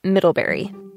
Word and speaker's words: Middlebury: Yeah, it's Middlebury.